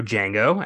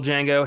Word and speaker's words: Django: Franco Nero, who plays the original Django, has a cameo appearance as the Italian slave owner engaging Calvin Candy in the Mandingo fight. He has that, you know, the D is silent moment with our Django at the bar, which is so fucking rewarding if you've seen the 60s movie, which is awesome.